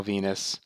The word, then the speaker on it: Venis: It was Mark Henry and Val Venis. Rhymes with penis.